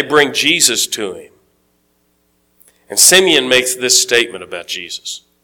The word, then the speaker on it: Simeon: And Simon, depending on your pronunciation of the word, it's got an E, so Simeon is probably closer to right.